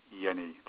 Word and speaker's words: Jenny: Hans Yeni, that's spelled J-E-N-N-Y.